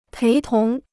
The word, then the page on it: 陪同: péi tóng